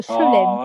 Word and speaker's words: chelem: C-H-L-E-W-E-M. – Not bad at all, but it's a schlem. – Oh, okay. C-H-E-L-E-M. – It's C-H-E-L-E-M.